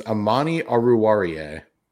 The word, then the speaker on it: Amani Oruwariye: From the Detroit Lions, Amani Oruwariye.